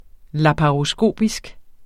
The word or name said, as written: laparoskopisk